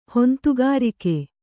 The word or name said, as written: ಹೊಂತುಗಾರಿಕೆ